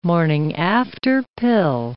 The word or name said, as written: morning-after pill